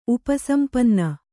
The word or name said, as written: ಉಪಸಂಪನ್ನ